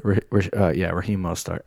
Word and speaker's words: Raheem Mostert: And they still got, was it Raheem Mostert? Is that how you say it? – Raheem Mostert.